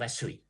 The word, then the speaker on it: ชเว: 1816 นะครับแล้วก็ควบเมืองคนพื้นถิ่นเขาออกเสียงเป็นสแวร์จริงๆผมเชื่อว่ารากนี้มันมาจากคำว่าชเวซึ่งเป็นภาษาหมอนแปลว่าทองคำที่พม่าออกเสียงว่าสุย